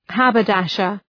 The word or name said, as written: haberdasher